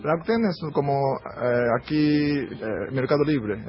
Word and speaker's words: Rakuten: Ahí está, Rakuten. Rakuten, ¿no? ¿Cómo se dice? – Rakuten.